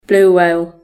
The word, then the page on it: blue whale: British English